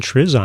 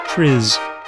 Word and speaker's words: TRIZ: Maybe it's time to apply some Triz on it. Triz. Capital T, capital R, capital I, capital Z. Triz. Triz is an acronym, but it's a Russian acronym, and I'm going to do my best to pronounce it.